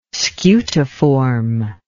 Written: scutiform

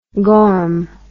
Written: gorm

